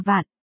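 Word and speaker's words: vạn (卍): đọc là vạn,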